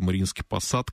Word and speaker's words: Мариинский Посад: Это Мариинский Посад, Канаш и Шумерля.